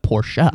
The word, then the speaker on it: Porsche: And I would be like, oh, it's a Porsche. He's like, Porsche.